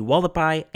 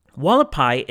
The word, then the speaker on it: Hualapai: For example, Hualapai is spelled as it would be pronounced in Spanish, so H-U-A-L-A-P-A-I.